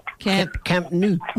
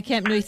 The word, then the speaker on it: Camp Nou: And, of course, they sent me a photo of the Camp Nou. How do you pronounce it? Come on, tell me. – Camp Nou. – Okay, Camp Nou, thank you.